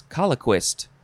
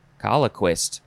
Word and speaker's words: colloquist: Next is how do you say this? Colloquist. Colloquist.